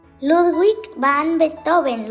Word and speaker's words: Ludwig van Beethoven: Ludwig van Beethoven, ¿lo dije bien? – Sí, pecas, lo dijiste bien.